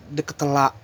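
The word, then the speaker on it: De Ketelaere: tulisannya Charles Charles Charles biasa Charles terus DKT Laire tapi dibacanya pas gue ngeliat postingan Instagramnya A9 si Charles ini bilang Charles de Ketela de Ketela kayak gitu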